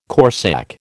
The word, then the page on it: corsak: American English